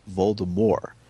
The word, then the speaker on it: Voldemort: Second of all, when I was on the pronunciation guide on Scholastic, I noticed that when you click on Voldemort, it pronounces it Voldemort. It leaves off the T completely.